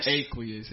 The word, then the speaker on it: Aqueous: Aquarius. – Yes. – That's what I said it right? – It's Aqueous. – Aqueous. Okay.